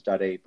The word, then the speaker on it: Dzadey: So Alfred, and then surname is Dzadey, but it's spelled Dzadey.